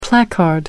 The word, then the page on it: placard: American English